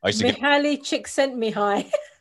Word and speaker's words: Mihaly Csikszentmihalyi: Mihaly Csikszentmihalyi.